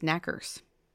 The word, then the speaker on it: Knockers: The name Knockers is actually pronounced Knackers.